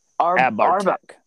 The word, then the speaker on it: Abhartach: Arbatek. – Arbatek. I'm sorry. – Yeah. I had to practice. – Arbatek. – Abhartach. Abhartek.